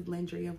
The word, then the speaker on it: Lendria: For those of you who don't know me, my name is Lendria Nguyen. That's like Andrea with an L, for those of you that need help pronouncing it.